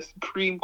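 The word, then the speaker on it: Supream: Yeah, so I think one team was called Supreme Team, and the Supreme was spelled P-R-E-A-M to rhyme with team, which is very bizarre.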